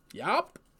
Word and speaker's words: UOP: It's UOP. Yop. – Cool. Okay. – And what it stands for is unorganized player, which is why it's spelled with a U and sounds like yop, because it's to accentuate how they play, right?